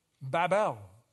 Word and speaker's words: Babel: Babel. Babel. Better pronunciation from the Hebrew. Bab meaning door. El, God.